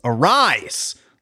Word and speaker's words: Arraez: He was like, how about that Arraez guy on the Twins? And I said, it's a Luis Arraez. That's how you pronounce it.